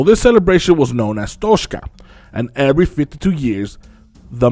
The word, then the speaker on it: Toxcatl: When I was looking into it, every time someone will enunciate it in text, it would always sound like the word was Toxcatl, because it's spelled T-O-X-C-A-T-L. There was only one YouTube video of this Mexican professor from Mexico City, and she pronounced it Toxca. So that's the enunciation I'm going to go with.